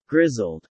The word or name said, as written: grizzled